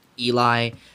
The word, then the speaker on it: Elie: When I do, you'll find it with my name, just Eli. It's spelled E-L-I-E.